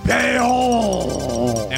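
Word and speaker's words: Beyoncé: I'm not sure you said her name right. – Oh yes. What is her name? – It is pronounced Beyonce.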